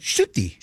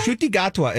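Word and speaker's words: Ncuti: Nikuti is how you think you'd say it. – How do you say it? – Shuti. – Shuti. – Shuti. Oh. – Shuti Gatwa. Okay. – Shuti Gatwa is how you say his name.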